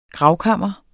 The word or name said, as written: GRAVKAMRES